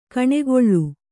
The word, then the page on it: ಗಾನಕಲೆ: gāna kale